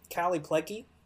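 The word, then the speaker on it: Kallie Plagge: Most importantly is Callie Plagi, is how you say her name.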